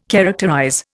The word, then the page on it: characterize: Indian